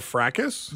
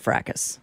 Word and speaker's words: fracas: Which one would be the proper pronunciation? – I would say it's a fracas. – It's a fracas. Say it again. – Fracas.